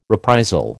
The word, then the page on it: reprisal: American English